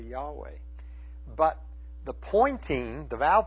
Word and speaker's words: Yahweh: And so now most scholars believe that the proper pronunciation of the word is Yahweh, though there's no way to know what the proper pronunciation is, because all you have is four consonants.